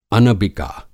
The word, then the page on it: अनबिका: Hindi